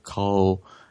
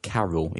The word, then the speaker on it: Carel: Anyway, Orania itself was founded by a chap called Carl Bossoff the Third. Now, if you look at the English spelling, it looks like Carol. It's spelled C-A-R-E-L, but it's actually Carl.